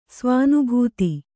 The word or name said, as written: ಸ್ವಾನುಭೂತಿ